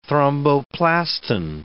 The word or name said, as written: thromboplastin